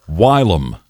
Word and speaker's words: whilom: Your word today is whilom. – W-H? – Yes. – Y-L? – No, I-L-O-M. Sometimes pronounced as whilom. But usually whilom.